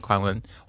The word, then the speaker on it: Kuan-wen: No, I'm Kuan-wen.